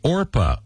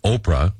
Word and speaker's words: Orpah: Oprah Winfrey shared that on her birth certificate, her given name was actually Orpah, O-R-P-A-H, from the Bible's The Story of Ruth. But relatives kept mispronouncing it Oprah, and it just stuck.